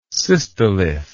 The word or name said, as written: cystolith